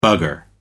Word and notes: bugger: American English

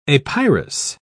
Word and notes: apyrous: British English

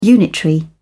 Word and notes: unitary: British English

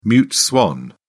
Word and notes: mute swan: British English

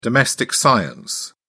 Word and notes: domestic science: British English

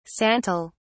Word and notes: santol: British English

